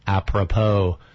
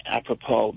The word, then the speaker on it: apropos: I thought that was apropos, talking about Sartre and existentialism. – Totally apropos, because...